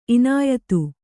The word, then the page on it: ಇನಾಯತ: kannada